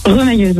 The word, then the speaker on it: remailleuse: Est rémailleuse. C'est ce que c'est? – Rémailleuse. – C'est ça, avec les mailles. – Remailleuse. – Ça se prononce pas comme ça? Remailleuse? – Remailleuse, oui. – Remailleuse, pardon.